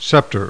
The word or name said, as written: SCEPTER